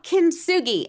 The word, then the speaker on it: Kintsugi: So it's ultimately a story of the, how did you pronounce that? – It's called Kintsugi, and I can send it to you.